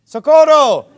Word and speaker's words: socorro: Socorro!